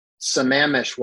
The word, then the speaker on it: Sammamish: My understanding is it's in Sammamish, Washington.